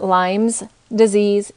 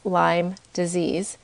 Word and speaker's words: Lyme disease: Although many people think of Lyme disease as an East Coast disease, it originated in Lyme, Connecticut, and FYI, it's spelled L-Y-M-E, and it's not Lyme's disease, it's Lyme disease.